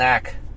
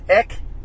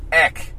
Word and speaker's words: Aeck: Okay, so the A is silent. – Go talk to my great-great-great-great... – So... – Great-grandfather. – Colton's last name is A-E-C-K. If you guys are wondering how to say it, and you're at home, and you're saying his name, leave out the A.